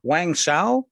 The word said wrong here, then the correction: Wang Zhao: Wang Zhao, close enough. – Okay. So you say it for me, please. – Wang Zhao.